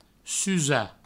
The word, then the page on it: susar: provençal; susa